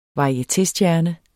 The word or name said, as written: varietéstjerne